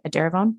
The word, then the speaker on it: Edaravone: But you mentioned a couple of times the infusions, and I wanted to talk about that because Jessie chose to take a drug called Edaravone. I'm pronouncing that right? Edaravone? – Yes. Yes.